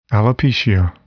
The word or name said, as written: alopecia